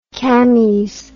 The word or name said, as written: cammies